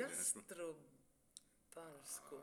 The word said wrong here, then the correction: Jastrebarsko: Ah, var det så? Ja. – Du, förmodligen har du rätt. Ska vi se på uttalet bara. Jastrebarsko.